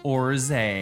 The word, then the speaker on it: orgeat: Just click on orge, and then another a window pops up, and it says pronounced orge, a syrup of almonds, orange flower water, and sometimes barley water, often used in tropical and other cocktails, now always non-alcoholic.